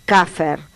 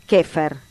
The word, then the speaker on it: Käfer: En alemán, Käfer, pronunciación aproximada, Käfer.